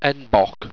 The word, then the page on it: enbok: Owstwecian